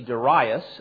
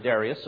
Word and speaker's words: Darius: The proper pronunciation may be Darius. This happened to be my father's name, Darius. That's the way he pronounced it, Darius, so I'm stuck with that. That's the way I'm going to pronounce it.